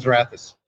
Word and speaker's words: Xrathus: RevoChem and Xerathus. – Xerathus. – It's an online collaboration. – Okay, so Xerathus, which is X-R-A-T-H-U-S.